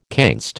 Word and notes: canst: American English

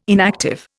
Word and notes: inactive: Indian

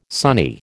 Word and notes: sonny: American English

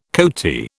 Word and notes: coati: British English

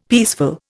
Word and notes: peaceful: Indian

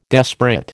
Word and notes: desperate: American English

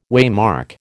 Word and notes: waymark: American English

